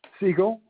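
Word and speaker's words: Sigel: Siegel, like the bird.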